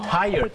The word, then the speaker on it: tired: เหนื่อยนะครับอย่าลืมครับออกเสียง tired สองพยางค์นะครับ T-I-R-E-D ไม่ใช่ try รอเรือแล้วก็ไม่ใช่ไทยพยางค์เดียวนะฮะ